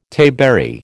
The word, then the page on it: tayberry: American English